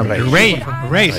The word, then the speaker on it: Raye: Ray, se escribe Raye con Raye.